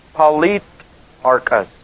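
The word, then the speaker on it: politarches: The rulers of the city, the Greek word is politarchus, archate. P-O-L-I-T-A-R-C-H, long E-S.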